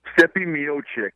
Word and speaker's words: Miocic: How do you pronounce that last name? – Stipe Miocic. – Okay, thanks a lot.